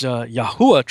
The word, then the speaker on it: Yahuwah: And it was always pronounced Yahu. So that's why I called it the Yahuwah Triangles. That's why I settled on the pronunciation of Yahuwah. But it also, just to be frank, rhymed with Bermuda.